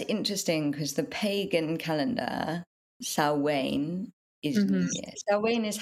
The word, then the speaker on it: Samhain: It's also the Samhain, kind of Samhain, is that how you say it? Samhain, kind of. – Yeah, Samhain is another. – Yeah. – Transition. Samhain is another word that people can't say based on how it's spelled.